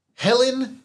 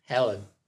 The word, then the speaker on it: Hellyn: Dracula Castle is endangered during the 18th century by a pirate named, very aptly, Helen DeVille. Helen is spelled H-E-L-L-Y-N, and DeVille is spelled D-E, capital V-I-L-L. – Helen.